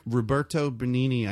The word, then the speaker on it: Roberto Benigni: Roberto Benigni, I think... I didn't pronounce his name right. – You did. – But... Did I? Mm-hmm.